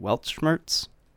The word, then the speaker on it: Weltschmerz: It's called Weltschmerz. – I believe that is how it's pronounced, yes.